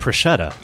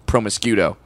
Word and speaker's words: prosciutto: He said prosciutto. – Yeah. Well, and also until about 2 years ago, I thought the meat was promiscuito.